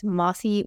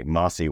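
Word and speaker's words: Mosse: It's counterintuitive. It's Mossy Weinstein. – Okay, Mossy Weinstein.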